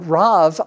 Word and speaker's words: rav: It comes from the word rav, R-A-V, you would say it in English. Rav means great.